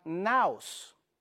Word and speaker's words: naos: naos, N-A-O-S, naos, así como se pronuncia.